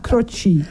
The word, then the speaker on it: Croce: Oh, spreek je dat zo uit? – Ja, zo spreek je het uit. Stond erbij, tussen haakjes. – Oh. – Zo spreek je het uit, Croce.